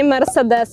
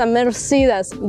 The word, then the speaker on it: Mercedes: еще одна марка машины, которую в Америке произносят совсем не так, как вы привыкли, это не Mercedes, это Mercedes.